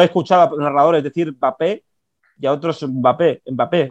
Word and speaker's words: Mbappé: Eh, hay gente que le llama eh, Mbappé, como la, M se oye muy poco, pero sigue sonando. Y otros que dicen Mbappé.